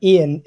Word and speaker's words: Iain: Ian, is spelled I-A-I-N.